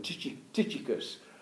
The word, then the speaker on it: Tychicus: Now, I don't know how you said this name, but I call him Tychicus. Tychicus, is that how you say it? – Something like that, yeah.